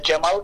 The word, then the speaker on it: Cemal: Uh, Cemal That's how we pronounce in, in Turkish.